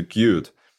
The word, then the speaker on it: Qt: It's pronounced Qt, right? – Yep. Qt, yeah, exactly. Qt.